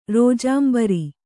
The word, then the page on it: ರೋಜಾಂಬರಿ: kannada